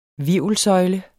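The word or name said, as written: HVIRVELSØJLE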